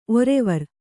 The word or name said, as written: ಒರುವಾರ